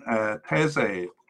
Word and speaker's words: Teze: And then a couple of possible candidates in, those players are likely, and possible candidates in Jurrien Timber and Jordan Teze, I think is how you say his name. T-E-Z-E for those on the podcast.